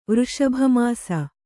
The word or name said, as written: ವೃಷಭಮಾಸ